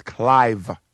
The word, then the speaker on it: Clive: But my name is Clive, C-L-I-V-E, like Clive Davis, who gave Whitney Houston, her big break.